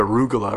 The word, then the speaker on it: arugula: Did I pronounce that right? Because I always have trouble with that word. – Arugula. – Excellent. Good. I pronounced it right for the first time.